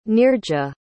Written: neerja

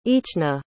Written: eachna

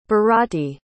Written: bharati

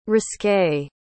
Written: risqué

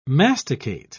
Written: masticate

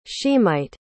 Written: shemite

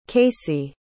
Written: kasey

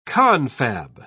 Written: confab

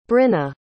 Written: bryna

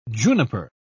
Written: juniper

